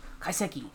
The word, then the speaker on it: Kaiseki: Kaiseki, which means that really the chef is preparing this for you.